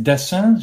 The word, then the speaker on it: Dessin: David Dessin, zeg ik dat goed? – Ja, ik het wel. – je, je spelt D-E-S-S-I-N, Nico.